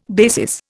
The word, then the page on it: basis: Indian